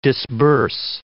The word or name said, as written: disburse